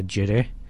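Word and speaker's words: girih: She pronounces it as gireh, which is the correct pronunciation.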